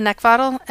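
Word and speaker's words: Neckvatal: My name, Brenda Neckvatel, and that's spelled N-E-C-K, like the thing you want to choke, V as in Victor, A-T-A-L.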